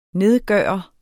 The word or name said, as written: nedgøre